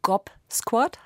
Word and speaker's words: Gob Squad: Also Gobsquad heißt sie. Also das ist auch wieder ein Wortspiel. Es gibt, wie Sie eben auch falsch ausgesprochen haben, die God Squad. – Entschuldigung, ich wollte Gob Squad sagen, aber jetzt kann ich es wirklich.